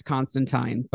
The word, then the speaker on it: Constantine: And before anyone corrects me, because this is a podcast about a British comic, I do know it's pronounced Constantine, but I'm a classics major and it just never comes out of my mouth that way.